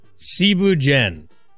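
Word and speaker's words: Thieboudienne: First off, Cebu Jen. It's spelt like Thibu Dien.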